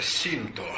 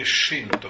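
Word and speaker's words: шинто (神道): Э, традиционная японская религия называется синто или шинто. – Вообще, все, что мы произносим «с», японцы, как оказалось, произносят как «ша». Не «соня», «шоня», «митсубиши», вот Бушидо и так далее. Поэтому, видим Шинто.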